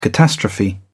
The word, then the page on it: catastrophe: British English